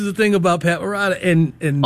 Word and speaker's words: Morita: Yeah, I've been saying this, Morita. That's how you say it. Yes, Morita, yes.